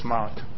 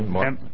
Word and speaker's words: Mark: It's Mark. – Hi, could you spell it for me? – Mark. It's just Mark, M-A-R-K.